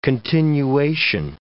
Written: continuation

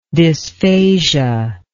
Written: dysphasic

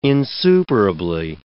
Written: insuperably

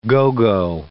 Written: go-go

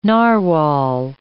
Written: narwhal

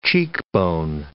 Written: cheekbone

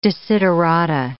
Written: desiderata

